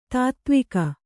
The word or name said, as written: ತೊತ್ತುವಗ